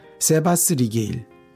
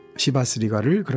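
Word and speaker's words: Chivas Regal: 10분도 되지 않아서 한 행상인이 세바스 리게일, 괄호 열고 시바스 리가를 그렇게 발음했다.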